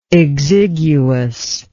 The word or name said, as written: exiguousness